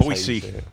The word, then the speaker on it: Boise: That's Boise. – That's how you say it. A true Idahoan told me.